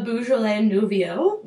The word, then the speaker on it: Beaujolais Nouveau: I think our guest knows how to pronounce it a little better. – A Beaujolais Nouveau.